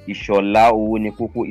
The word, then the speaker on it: Ishola Owonikoko: And I can also be contacted through my Gmail, which is Ishola Owonikoko.